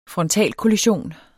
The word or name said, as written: frontalkollision